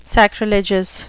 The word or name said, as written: sacrilegious